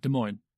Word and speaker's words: Des Moines: Des Moines.